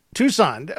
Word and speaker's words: Tucson: Tucson? What is that? Arizona. – Tucson. – Oh, wow. – Yeah, the sea is silent.